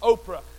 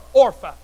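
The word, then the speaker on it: Orpah: Some Bible commentators pronounce her name as Oprah. I refuse to put Oprah in the Bible. Somebody say amen right there. So we're going to call her Orpha.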